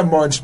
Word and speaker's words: Monge: me, lo estás pronunciando ya como en, Es que el apellido es francés, pero casi es, Monge. Se pronuncia Monch.